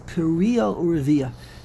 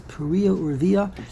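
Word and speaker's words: Puriya Urviya (פריה ורביה): No, but yeah, over here in this gemara, a perush, and while the pasuk means piriya urviya. The correct pronunciation is Puriya Urviya, and you see a part iske Kirimoina, Ravi large ones, Ravi iske Kirimoina.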